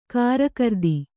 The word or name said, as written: ಕಾರಕಿರಿದಿ